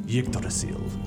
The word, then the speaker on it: Yggdrasil: And all that has happened, all the regions of the world lie under the branches of the ash, Yggdrasil. And that's actually pronounced Yggdrasil.